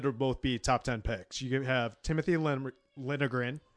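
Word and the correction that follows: Liljegren: No, I'm messing up that pronunciation. – Lilligren. – Lilligren. Okay, I got it.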